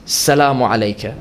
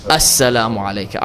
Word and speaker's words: As-salamu alayka: I emphasized at the beginning that the line is not to be pronounced Salamu alayka. I said it's to be pronounced As-salamu alayka.